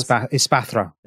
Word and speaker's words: Espathra: Okay, so Tulip has Ferrigraph, Gardevoir, Espartha. How do we say this? – Espathra.